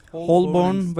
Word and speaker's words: Holborn: Konferans tam da Polanski'nin milletvekili adayı olduğu ara seçimden, Holborn ve St. Pancras ara seçiminden, eğer doğru telaffuz edebildiysem tabii, birkaç gün öncesine denk geldi. Öyle değil mi? – Evet. Evet, doğru.